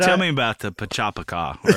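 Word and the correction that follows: PechaKucha: Pachakucha. – Pachakucha. – Yeah, I presented it. Did I say it right? – Yeah, you got it. – Pachakucha.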